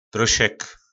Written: prosek

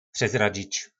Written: prezradzic

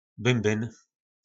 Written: bymbyn

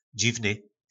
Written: dzivny

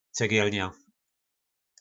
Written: cegelna

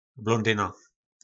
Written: blondyna

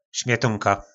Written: smetonka

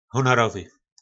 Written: honorovy

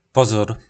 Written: pozor

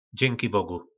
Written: Dzynkibogu

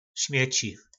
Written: smeci

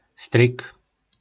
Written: Stryk

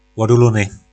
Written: lodulony

